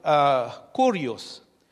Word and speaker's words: kurios: Now, the Greek word kurios, K-U, but it's pronounced kurios, means master and king.